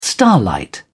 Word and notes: starlight: British English

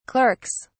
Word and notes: clerks: British English

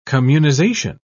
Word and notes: communization: British English

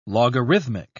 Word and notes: logarithmic: British English

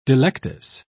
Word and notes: delectus: British English

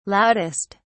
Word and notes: loudest: British English

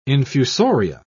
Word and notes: infusoria: British English

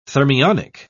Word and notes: thermionic: British English

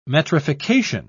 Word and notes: metrification: British English

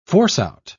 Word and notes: force-out: British English

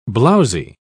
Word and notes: blowzy: British English

